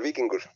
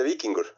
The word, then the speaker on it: Víkingur: Vamos a hablar con Pablo Punyed desde Islandia, seleccionado nacional hace algunos años y todavía militante del Víkingur, según entiendo, si lo pronuncio bien. ¿Qué tal, Pablo? ¿Cómo estás? Bienvenido al Wiri Wiri al aire, te escucha a todo El Salvador. – Hola, buenas tardes, ya buenas noches de mi parte. Gracias por tenerme aquí. Y sí, bien pronunciado, el Víkingur. – Okay. No, es que eso le iba a preguntar. ¿Cómo se dice ya en serio? – Se dice Víkingur.